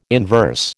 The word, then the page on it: inverse: American English